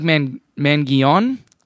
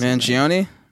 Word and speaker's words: Mangione: How the hell do you pronounce Mangione? – Mangione. Oh, yeah. Look, Chuck Mangione. It might be that.